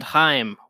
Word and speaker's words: Haim: Yes, the band Haim, which I had to double check I was saying it correctly because it rhymes with time.